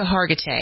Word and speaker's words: Hargitay: Mariska Hargitay.